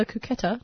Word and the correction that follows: Cocteau: Now, my producers just told me that it's actually pronounced Cocteau Twins.